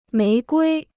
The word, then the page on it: 玫瑰: méi guī